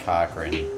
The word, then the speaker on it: Cochran: Mike Cochran, where Cochran is spelled C-O-C-H-R-A-N.